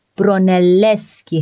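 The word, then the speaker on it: Brunelleschi: تلفظ صحیح اسم طراح گنبد کلیسای سانتا ماریا دل فیوره فیلیپو برونلسکی هست با یه تشدید روی ل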